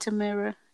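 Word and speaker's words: Tamera: You know those twins, Tia and Tamari? Did I say it right? – Tia and Tamira, yeah.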